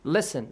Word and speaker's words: listen: Can you spell the word listen? Listen to me. – Listen. – Listen. – Listen. It's written like listen, yeah. – But T is silent there.